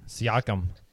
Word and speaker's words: Siakam: Pascal, how do you say his last name? Siakam? – Siakam.